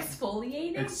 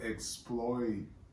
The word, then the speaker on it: exfoliating: And did I say that right? – Exfoliating? – There you go. Because I didn't say the other word that doesn't, that no. – It's not a word. – No, I said exploiterating.